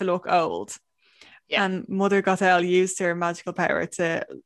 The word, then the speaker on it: Gothel: So Mother Gothel, I don't think I'm pronouncing that right, Gothel from Tangled. Gothel. – Yes.